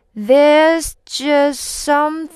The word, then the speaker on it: there's just some: there's just some things. 好，there's just 的不发音了，和后面的 something 的 s 直接连在一起，两个 s 发一个 s 的音。